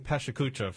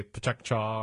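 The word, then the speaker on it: PechaKucha: I've been saying Pashakucha for several years now, but then if you look in Wikipedia and watch YouTube, there's a variety of pronunciations. It comes out of Japan, and, mm-hmm. and I've heard people say Pachakcha or Pekchakcha, or I think we can stick with Pashakucha for our purposes here now.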